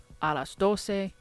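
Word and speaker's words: alas dose: It's spelled D-O-C-E. And in Filipino, it is D-O-S-E. So again, 12 o'clock is a las doce, spelled A-L-A-S space D-O-S-E. So a las doce, literally almost the exact same thing as Spanish.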